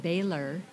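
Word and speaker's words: Bahler: And he did, and he had to make sure he said the name right because our last name is you say it Baylor, but um, it's not spelt like Baylor University. It's B A H L E R, so it sounds like baller.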